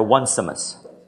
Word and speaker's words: Onesimus: You do realize that that is a mispronunciation of the man's name. Some of you are looking like, well, I thought that's what his name was. It's Onesimus, okay?